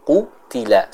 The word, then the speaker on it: qutila: Di sana kita perhatikan huruf qafnya harus dibaca dengan tebal ya. Dalam artian pengucapannya harus dibedakan dengan huruf kaf. Bukan qutila, kutila, tapi ku, kutila, kutila, seperti itu. Bedakan antara pengucapan kutila dengan kaf dan kutila dengan huruf qaf ya.